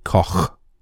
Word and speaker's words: Koch: For those of you who are giggling at this, because I'm sure some of you have, it's K-O-C-H, okay, Koch, but I can't pronounce it in a German word all the time, so I'm going to say Koch.